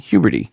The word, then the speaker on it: Huberty: And this time it was Katie rhymes with puberty, Huberty, whom said she disagrees with other Apple analysts because, well, that's the only way she can get coverage.